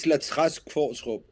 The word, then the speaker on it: Latrache: It's Latrache.